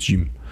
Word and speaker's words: Gims: Gims, G-I-M-S. Ich glaube, ausgesprochen wird Jim.